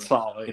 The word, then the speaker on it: Saleh: I hope we are with Robert Saleh. I hope I'm pronouncing his last name right. But Robert Saleh, yes, sir.